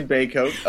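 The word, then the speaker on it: Bacote: I got it right? – Some relatives do pronounce it that way, yes. Oh. I say Bacot. – Bacot, I'm sorry.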